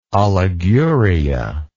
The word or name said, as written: oliguria